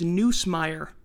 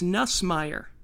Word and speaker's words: Nussmeier: He kept pronouncing Garrett Nussmeier's name as Neusmeier. He couldn't get the backup quarterback's name right. It's Nussmeier.